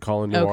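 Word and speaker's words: Colion Noir: It's like, but it's not spelled Colin. – I know, but he pronounces it Colin Noir.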